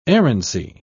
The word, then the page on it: errancy: British English